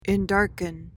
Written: endarken

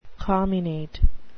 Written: comminate

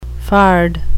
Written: fard

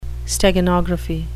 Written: steganography

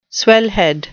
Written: swellhead

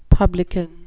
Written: publican

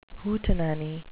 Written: hootenanny

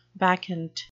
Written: bacchant

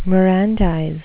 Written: mirandize

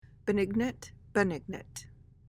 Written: benignant

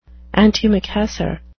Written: antimacassar

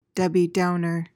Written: Debbie Downer

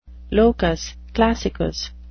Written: locus classicus

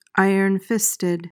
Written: ironfisted

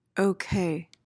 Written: ok

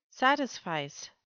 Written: satisfice

